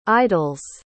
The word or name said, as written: idol's